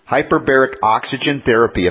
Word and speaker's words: hyperbaric oxygen therapy: It's called HBOT therapy, hyperbaric oxygen therapy. If I said that correctly, Michelle? – Yeah, that's correct.